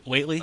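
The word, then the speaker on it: Whateley: Or Whately. I think it's pronounced Whately, but W H A T L E Y, right? – Yeah, in the movie.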